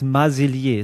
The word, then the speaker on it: Marsilier: Max Marsilier, ist das richtig ausgesprochen? – Genau, perfekt. Super. – Französisch, ja? Also genau, französische genau. Aussprache.